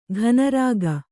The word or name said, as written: ಘನರಾಗ